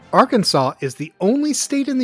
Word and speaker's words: Arkansas: In Arkansas, it's a felony to mispronounce the state name, so remember, it rhymes with Santa Claus.